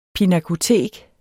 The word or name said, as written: pinakotek